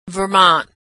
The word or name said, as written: Vermont